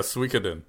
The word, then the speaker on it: Suikoden: I never get to pronounce games. Suikoden Tactics. – That's very close. Yeah, Suikoden.